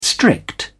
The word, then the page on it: strict: British English